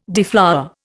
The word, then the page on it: deflower: Indian